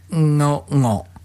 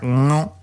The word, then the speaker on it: ngo: No, it's spelled N-G-O, okay? But so we would something like Ngo, okay? That's like the end of the word sing, like ng, and then with an O after it, so it's like Ngo, Ngo. That's how you say it.